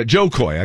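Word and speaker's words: Jo Koy: Oh, boy, if you could paint the picture of Taylor Swift and the look she had on her face after Kojoy ended up making fun of... Wait, did I say his name right? Yeah, Joe Coy. I got it backwards. Kojoy. It's it's Joe Coy.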